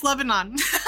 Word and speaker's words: Lebanon: Meckley's Brooms from LeBron. – I'm pronouncing that right. – LeBron, Pennsylvania. Did I say that town name correct too? Hello. – No. It's Lebanon.